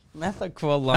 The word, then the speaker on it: Methaqualone: Is that how we pronounce it? – Methaqualone? – Methaqualone.